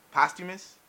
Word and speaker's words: posthumous: it actually is one of the biggest posthumous, as my co-host helped me to pronounce, debut in 23 years since Tupac Shakur and Notorious B.I.G.